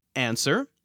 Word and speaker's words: answer: I can only think of two other words in the entire English language that have a silent standalone W. They are answer, A-N-S-W-E-R, and sword, S-W-O-R-D.